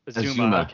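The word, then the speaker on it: Uzomah: There's a Bengals fan at the office. I think it's Azuma. – Azuma. Okay, yeah. Azuma.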